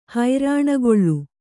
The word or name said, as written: ಹರಣಗೊಳ್